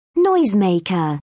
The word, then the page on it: noisemaker: British English